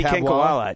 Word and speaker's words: Kinkhabwala: Is that properly pronounced? – Aditi Kinkhabwala. It shouldn't be. Yeah, we have heard her name many times, right? – I don't know if I've ever read it on the podcast. – It was a little sluggish in your pronunciation, but it was correct. – I think, soft B, Dan.